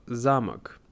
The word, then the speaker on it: замок: В первую очередь а, слово «замок». Важно заметить ударение «замок», не «замок», потому что это другое значение.